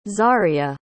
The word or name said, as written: zaria